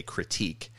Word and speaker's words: kritik: It's spelled K-R-I-T-I-K, critique.